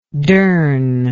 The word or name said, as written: dern